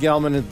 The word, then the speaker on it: Gelman: My name is Alan Gelman. G-E-L-M-A-N. It's very easy to spell and pronounce.